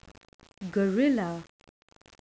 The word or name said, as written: gorilla